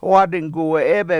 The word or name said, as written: wAAdinguwe7EEbe7